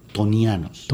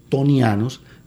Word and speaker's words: ctonianos: A esos núcleos desnudos de planetas gigantes los han llamado tonianos. – ¿Tonianos? ¿Qué significa eso? – Yo en realidad el origen es una palabra griega que se escribe con C al principio. – C-T-O-N-I-A-N-O-S.